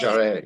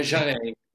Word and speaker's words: Jarry: And Frost and Parry, Alva Jarry, J-A-R-R-Y-P-A-R-R-Y. – I knew it had to be a Parry, but I couldn't think of a name that had Parry. – It rhymes with Parry, yeah.